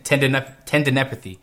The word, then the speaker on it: tendinopathy: I definitely would have um, not said that correctly. Ten, tendinopathy.